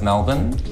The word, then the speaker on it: Melbourne: Let's go around, and the pronunciation of Melbourne. – Melbourne. – Of course. – Nailed it. – Melbourne. – Melbourne. – Nailed it. Round of applause. Yeah. We like to throw away our vowels in Australia.